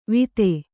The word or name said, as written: ವಿತೆ